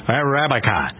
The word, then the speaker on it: Arabica: Arabica. A-R-A-B-I-C-A. I pronounce that right? Arabica coffee. Good.